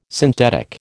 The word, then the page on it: synthetic: American English